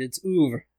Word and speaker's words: oeuvre: The way you pronounce it, it sounds like it's spelled O-V-E-U-R. – Oeuvre. You know what's fucked up? – And you're not even supposed to pronounce the R-E that hard. It's oeuvre.